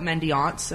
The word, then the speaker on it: mendiants: Also, chocolate mendiants.